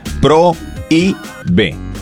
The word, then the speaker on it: prohíbe: Porque tiene un hiato. La O y la I se pronuncian en sílabas distintas. Pro, I, B. ¿Ok? – Uh-huh. – Pro, I, B. Esa es la división que se hace. Entonces, es un hiato y va tildado.